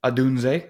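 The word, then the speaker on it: Odunze: Adunze, that one I should just know.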